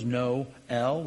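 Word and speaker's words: Noël: It goes from N-O-W-E-L-L to N-O with the umlaut over the E and an L, making us, if you know the languages, to separate a diphthong, those vowels together, so that we pronounce both of those, no, L, we pronounce both of those vowels.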